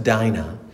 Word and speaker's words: Dina: A side note in 19th century America, the name Dina, pronounced Dinah, became a shorthand term for enslaved black women.